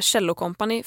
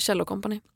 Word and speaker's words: Kjell & Company: Jag vill instinktivt säga Kjell & Company för att det är så min pappa har mispronounced it hela livet. Som att han pratar om en cello.